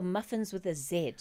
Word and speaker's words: Muffinz: It's Muffinz with a Z, because I know that often when we pronounce it, you can't tell the difference in how it's said. But that's just because as South Africans generally, we don't pay attention to these things. We're just like, give them muffins.